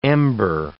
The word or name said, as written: ember